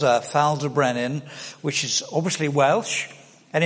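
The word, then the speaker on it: Ffald-y-Brenin: Because F in Welsh is two Fs. If it's only one F, it's pronounced V. So if you're using it to say F, you have two Fs, so double F, A-L-D, Faldebrennen.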